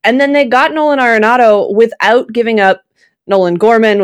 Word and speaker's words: Arenado: So I'm happy to know that I know how to pronounce his name correctly, and it's Arenado.